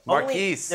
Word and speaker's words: Marques: And what, and what does uh, Marques Colston have to do to get his name pronounced uh, right by uh, Dan Hanzus? – Marquise.